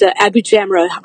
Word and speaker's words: Abujamra: My last name is Abujamra. Hard to pronounce for some people, but it sounds like I be jamming.